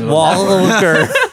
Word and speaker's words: Walker: You're overemphasizing the L. – I don't know what happens to me. – Walker.